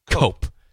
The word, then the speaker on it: Koop: But so thanks again to Nick Cope. Spelled K-O-O-P, but pronounced Cope.